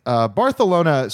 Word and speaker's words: Barcelona: Barcelona, saying it correctly, is the last chance straight guys have to make their favorite gay voice.